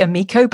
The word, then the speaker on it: Amico: Well, I say Amico, but I mean, it's an Esperanto word. I deliberately chose something that was like a universal word. Okay. And it means friend, not surprisingly.